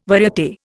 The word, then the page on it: variate: Indian